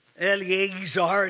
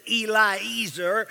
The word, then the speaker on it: Eliezer: Eliezer, as it's pronounced in some places. Eliezer is how it's actually pronounced, Eliezer.